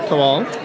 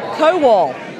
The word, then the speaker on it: Kowal: Kowal. – Kowal. Kowal. – That's how you say it, Kowal.